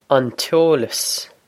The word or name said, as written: an t-eolas